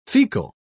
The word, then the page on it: faecal: British English